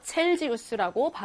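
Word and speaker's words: Celsius: 독일어에서는 grad Celsius라고 해서 Celsius라고 발음을 하면 돼요.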